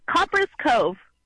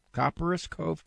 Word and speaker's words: Copperas Cove: Am I pronouncing that right? – Copperas Cove. – Copperas Cove, Texas.